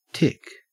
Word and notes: tick: Australian English

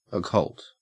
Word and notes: occult: Australian English

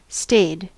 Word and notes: stayed: American English

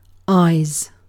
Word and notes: eyes: British English

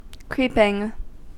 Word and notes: creeping: American English

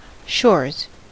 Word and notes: shores: American English

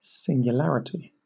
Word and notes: singularity: British English

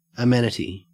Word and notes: amenity: Australian English

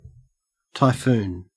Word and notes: typhoon: Australian English

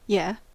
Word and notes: yeah: American English

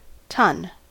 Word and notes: tonne: American English

